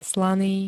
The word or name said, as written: slaný